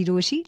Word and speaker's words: Rochie: मेरा नाम है मिर्ची रोशी, नाम की स्पेलिंग है ROCHIE, और मेरे साथ लाइन पर अभी मीशा है.